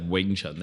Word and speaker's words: Wing Chun: By the way, you should just pronounce that Wing Chun. That's the VT spelling is just an alternate spelling of Wing Chun, but in Cantonese, it's all Wing Chun. Whether you spell it W-I-N-G-C-H-U-N, W-I-N-G-T-S-U-N, V-I-N-G-T-S-U-N, it's all Wing Chun.